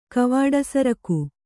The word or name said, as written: ಕವಾಡಸರಕು